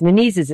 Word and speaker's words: Menezes: And I'm sorry, I mispronounced Father Wade Menezes. Menezes is how his name is pronounced, who's a favorite on EWTN.